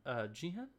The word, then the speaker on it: Jihen: Jihen, I think is how it's pronounced. J-I-H-E-N.